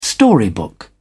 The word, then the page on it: storybook: British English